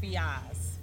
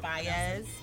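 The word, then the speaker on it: Faiyaz: Brent Fiaz. F-I-Y-A-Z. Fiaz. Fiaz. Fiaz.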